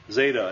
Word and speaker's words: Zeta: Zeta. And how's that pronounced? Z, as in adds. Eta, and that's the long E as in obey sound.